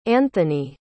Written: anthony

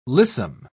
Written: lissom

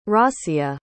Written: rasia

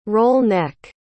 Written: roll-neck

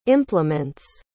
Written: implements

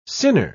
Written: sinner

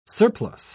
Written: surplus

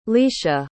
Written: leisha

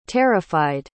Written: terrified

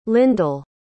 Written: lindel

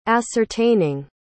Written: ascertaining

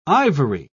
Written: Ivory

